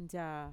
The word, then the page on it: ntxaa: Mixtec